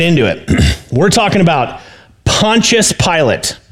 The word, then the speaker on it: Pontius Pilate: I think how you pronounce the name is Pontius Pilate.